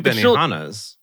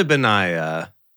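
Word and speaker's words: Benaiah: We have two Benihanas. Wow. – I know. What? Or Beni... How do you say that name? Really? – It's probably Benaya. – Benaya, but it does look like Benihana.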